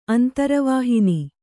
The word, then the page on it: ಅಂತರವಾಹಿನಿ: antaravāhini